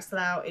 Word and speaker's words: Slough: Slow? S-L-O-U-G-H? – Slough.